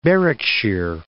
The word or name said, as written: Berwickshire